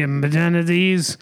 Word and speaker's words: amenities: And you have all the necessary amenities. How do you say it? Amenities, You're saying it perfectly.